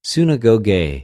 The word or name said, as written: συναγωγη